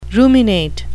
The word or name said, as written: ruminate